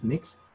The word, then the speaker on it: Mx: So I read online that there's a pronoun called, so it's spelled as MX, and it's pronounced as mix.